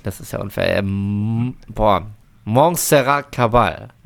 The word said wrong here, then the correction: Montserrat Caballé: Nein. – Caballé. – Caballé. Bitte nochmal als Ganzes zum Reinschneiden. – Montserrat Caballé.